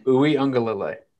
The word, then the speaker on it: Uiagalelei: Uiagalelei.